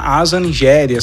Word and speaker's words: Aza Njeri: Tô falando da Asa Nigéria.